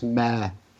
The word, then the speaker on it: Meyer: Now, this is weird because it's Sir Robert Meyer, M-E-Y-E-R, in the previous paragraph, and it's Lady Meyer, M-A-Y-E-R, in the next one. – That's because Meyer is often pronounced Mare here in the UK.